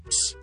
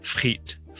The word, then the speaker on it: frites: 係點讀咧？係 frit frit frit 個 T 要讀嘅 S 唔使讀，但係嗰個 vowel 係 E 嚟嘅，就唔係 I 嚟嘅，所以我哋唔需要讀成 frights 而係讀 frit 就得啦。咁有時有 S，有時冇 S 啦，咁但係冇所謂。如果係法文就通常唔讀 S 嘅，咁所以咧 steak frites 就唔好讀成 steak fried， OK？